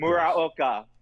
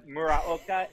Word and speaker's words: Muraoka: And it's Alan, A-L-A-N. – A-L-A-N, yes. – At Hoopers. – Muraoka. Good luck. Muraoka, M-U-R-A-O-K-A. That is butchered all the time.